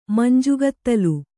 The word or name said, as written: ಮಂಜುಗತ್ತಲೆ